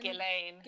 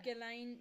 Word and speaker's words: Ghislaine: Ghislaine. – Ghislaine. – Ghislaine.